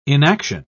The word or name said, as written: inaction